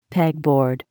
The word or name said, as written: pegboard